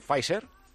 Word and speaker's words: Pfizer: ¿Lo he pronunciado bien, Pfizer? – Sí, lo has pronunciado fantástico.